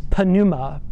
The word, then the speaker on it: pneuma: It's pneuma, P-N-E-U-M-A, or pneuma, if the P is silent.